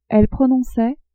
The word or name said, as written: elle prononçait